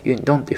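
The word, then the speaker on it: 运动: 発音はですね、ユンドンというふうに読みますので、今日はですね、アンタのニュースがありましたので、こちらの中国語とさせていただきます。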